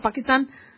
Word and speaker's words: Pakistan: Honestly, that's how you pronounce it, Pakistan. I don't like the way Obama pronounced it, but it is an ah. It's a schwa sound with that.